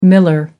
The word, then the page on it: miller: American English